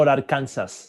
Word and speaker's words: Arkansas: Para lo que no lo sepáis, se pronuncian completamente diferente en inglés. – Pese a que la raíz es la misma. – Y Pese a que nosotros decimos Kansas y Arkansas, en inglés es Kansas y Arkansas.